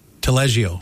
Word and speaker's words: Taleggio: But they've got the Drawing Room has a, am I saying this right? Taleggio dip? – Taleggio. – Taleggio. – Close.